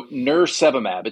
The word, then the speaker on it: nirsevimab: So nirsevimab, it's almost like nurse and then virus and then monoclonal antibody. So nirsevimab, right?